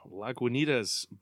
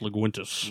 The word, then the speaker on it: Lagunitas: Also pronounced Laguintus. – Laguintus. – You know, every time I say that brewer's name, I'm convinced I say it wrong, so I just embrace it now. – Lagunitas. – Lagunitas.